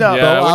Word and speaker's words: Boops boops: Unfortunately, Boops Boops is actually pronounced Bo-ops. Bo okay. as in bovine, ops as in optical, hence cow eyes, Steve."